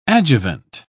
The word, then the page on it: adjuvant: British English